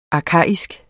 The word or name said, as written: arkaisk